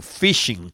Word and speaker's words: phishing: Eso se cataloga como phishing. Se escribe P-H-I-S-H-I-N-G. Phishing, como pescando, pero en lugar de con F, con PH.